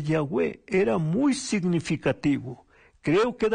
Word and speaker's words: Yahweh: En hebreo se describe con YHWH sin vocales. Cuando se agregan las vocales se pronuncia Yahweh, que en español sería Jehová.